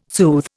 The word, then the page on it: soothe: Indian